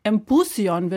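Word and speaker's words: Empuzjon: Empusion wird es da ausgesprochen und das klingt wie Symposion.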